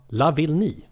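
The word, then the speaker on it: Lavillenie: Bengt Schött har ju rätt ut uttalet där för några år sedan, alltså Radiosportens uttalsguru. Man ska tänka på, vad vill ni? – Okej. – Okay. Och så säger man Lavigny.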